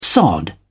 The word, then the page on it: sod: British English